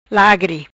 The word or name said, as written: Lagri